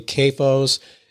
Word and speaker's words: CAFO: I would also say that your approach is completely different from, and I may be saying this incorrectly, CAFOs. It's short for concentrated animal feeding operations. So is CAFO, is that the right way to say it? – Yeah, that's correct.